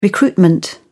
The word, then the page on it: recruitment: British English